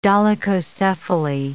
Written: dolichocephaly

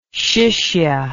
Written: shishya

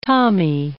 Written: tommy

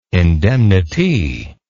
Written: indemnitee

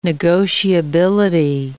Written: negotiability

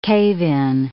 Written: cave-in

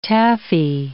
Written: taffy

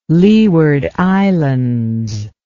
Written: leeward islands

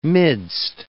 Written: midst

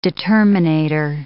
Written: determinator

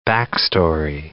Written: backstory

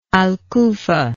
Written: al kufa

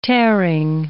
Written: tearing

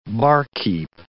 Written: barkeep

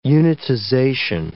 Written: unitization

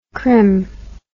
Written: crim

